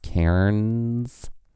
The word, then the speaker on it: Cairns: Who we had someone, we did have someone tweet us this week to tell us that Karens is technically pronounced Cairns. Like, it's a one syllable word.